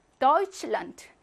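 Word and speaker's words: Deutschland: 테에스체하는 취로 발음하고 도이치와 란트, 독일의 나라라는 말이 합성 명사이기 때문에 강세는 앞에 오죠. 그래서 어떻게 발음합니까? 도이칠란트.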